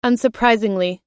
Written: unsurprisingly

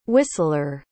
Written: hwistlere

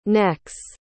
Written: necks

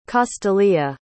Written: castalia

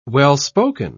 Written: well-spoken

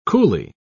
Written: coolly

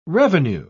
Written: revenue

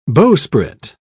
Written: bowsprit

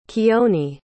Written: keoni

